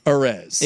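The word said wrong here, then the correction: Arráez: Arise. – Luis Arise, yeah.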